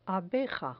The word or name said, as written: abeja